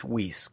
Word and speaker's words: Weiss: It's Weiss, okay? Can you say it right? – It is. Dale Weiss. – Dale Weiss. I always get that wrong. – Like Reese's Peanut Butter Cups. Weiss.